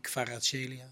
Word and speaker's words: Kvaratskhelia: Ik krijg ook nog de kans in de eerste minuten met die Kvaratskhelia. Zeg ik het goed? – Ja, heel goed. Kvaratskhelia.